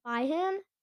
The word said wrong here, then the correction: Bijan: Byhan? Robinson? I don't know how to pronounce that. How to pronounce. How to pronounce. I. How to pronounce. Pronounce the name by. What? No, that's not very helpful. Let's see here. I'm gonna click on this video. How to pronounce it? How to pronounce? – Bijan. – Oh, it's Bijan. It's Bijan.